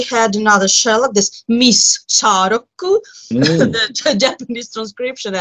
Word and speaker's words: Shārokku: Oh, well, you see, you have long vowels, so it's Sharoku, and then long consonants, and so on.